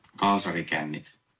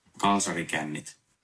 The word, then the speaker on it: kalsarikännit: Warte, ich habe ja auch einen Link zur Aussprache. Das muss ich mal eben an, anhören. Ich spiele das mal eben ab. – Kaasari kännit. – Kaasari kännit. – Kaas... Kaasari kännit. Mhm. Kaasari kännit.